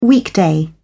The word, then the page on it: weekday: British English